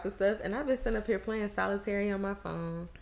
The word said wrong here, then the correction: solitaire: How do you say it? Solitaire.